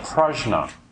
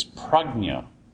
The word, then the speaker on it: prajna: The second word is, we call it prajna. And that's just actually bad pronunciation. The word in Sanskrit is prajna, which is made up of two words, pra and gna.